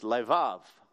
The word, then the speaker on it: levav: And the Hebrew word, which is read the other way around, is pronounced levav. Let's say that together. Levav. Levav. That's a nice word.